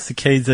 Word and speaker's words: saccades: R-E-M. – No. S-A-C-C-A-D-E-S. – Yeah, saccades, cicades. I think you could probably get away with either one.